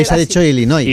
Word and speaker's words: Illinois: Se pronuncia Illinois. – Sí.